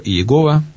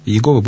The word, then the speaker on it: Иегова: Есть две традиции прочтения этих букв. Либо Иегова, либо Яхве. Яхве – более научная традиция, Иегова – более традиционная.